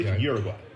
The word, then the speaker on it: Uruguay: Or, as you say it in English, Uruguay. – Oh, I like Uruguay much better.